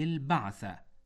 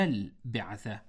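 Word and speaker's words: بَعثة: الصحيح في هذه الكلمة أن تكون بفتح الباء، أي البعثة، لا البعثة بكسر الباء،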